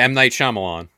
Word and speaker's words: M. Night Shyamalan: Amma Shyamalan, whatever his name is. I butchered his name. – Yeah, M. Night Shyamalan.